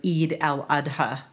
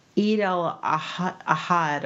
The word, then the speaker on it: Eid al-Adha: I will say that the actor butchered the word Eid al-Adha. Like, I didn't catch it until the third watch, what he was actually trying to say. Because he said Eid al-Ahad or something like that.